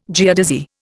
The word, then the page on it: geodesy: Indian